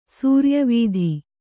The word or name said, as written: ಸೂರ್ಯವೀಧಿ